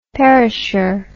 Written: perisher

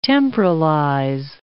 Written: temporalize

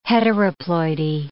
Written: heteroploidy

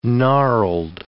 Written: gnarled

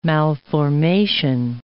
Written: malformation

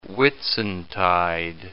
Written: Whitsuntide

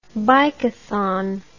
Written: bikathon

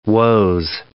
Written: Woese

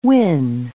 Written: wyn

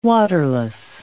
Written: waterless

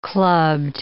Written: clubbed

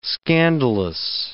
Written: scandalous

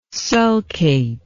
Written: sulcate

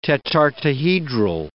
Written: tetartohedral

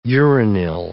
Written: uranyl

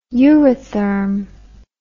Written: eurytherm

